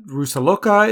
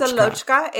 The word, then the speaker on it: Rusalochka: We're talking about uh, Rusaloka, is how it looks like it's spelled. They say Rusalka. Ru- oh, Rusalochka. Rusalochka is, I looked it up.